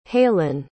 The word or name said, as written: halen